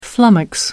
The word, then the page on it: flummox: American English